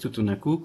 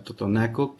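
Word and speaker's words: Tutunakú: Sí, lo pronunció bien, es Tutunacú, como bien lo menciona. Comúnmente estamos acostumbrados a señalar Totonaco, que es como se pronuncia mucho en español, pero lo dijo bien Tutunacú.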